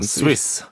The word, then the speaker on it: Swiss: Vad fan säger man? – Swiss. – Swiss Army Knife. – En Swish Army Knife. – Jag som just skröt om att jag hade varit i Schweiz efter ostfondy. Jag kan inte uttala.